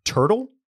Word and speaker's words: TRTL: from a brand that I believe is pronounced Turtle. It's TRTL, travel.